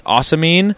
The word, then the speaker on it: ocimene: Okay, so the primary terpene is osamine, O-C-I-M-E-N-E.